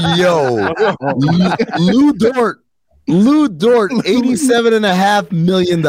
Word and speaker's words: Dort: Lou Dort. Yeah, Dort. – Yeah. – So it's like it rhymes with dork.